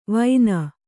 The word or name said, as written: ವೈನ